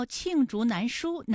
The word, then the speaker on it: 罄竹难书: 罄竹难书原意是用尽南山的竹子做竹简，都写不完他的罪行，后比喻事实极多，难以写完，多指罪恶，含贬义。 – 好，故事听完了，我们要揭晓正确答案了。你知道罄竹难书哪个字的声调是四声吗？